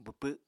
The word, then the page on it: bwpw: apatani